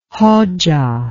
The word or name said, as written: Hoxha